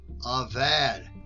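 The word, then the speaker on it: Avad (עָבַד): Strong's H5647. Avad. Avad. – Avad. You just heard the pronunciation.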